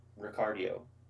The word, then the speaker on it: Ricciardo: It's just like, and also, side note, he pronounces his last name Ricardio. Like, he doesn't do it like the typical Italian.